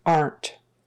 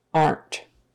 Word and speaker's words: aren't: That one we say as one syllable aren't, aren't. The contraction for are not, but we say it aren't.